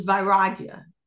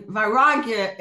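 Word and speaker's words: vairagya: And viragya, and please excuse my Sanskrit pronunciation, it's not great. I love the study, but my pronunciation is not great. But the word is described as, as viragya. I believe that's the way it's pronounced.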